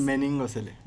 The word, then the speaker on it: meningocele: Eh, eh, tiene mielo melingocele, lo pronuncié bien. – Meningocele. – Meningocele, claro, porque tiene que ver con las meninges, ¿no? – Eh, eh.